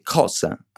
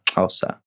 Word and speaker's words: Xhosa: Sarebbe Xhosa a volerlo leggere come scritto, ma si pronuncia Xhosa.